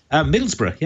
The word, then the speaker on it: Middlesbrough: Did I say that right? – Middlesbrough, yeah.